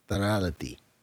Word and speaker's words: Tarality: The name of this token is called, I'm going to pronounce it the way they did on the call that I listened to, Tarality. T-A-R-A-L-I-T-Y, Tarality. That's on the Binance Smart Chain.